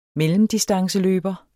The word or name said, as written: mellemdistanceløber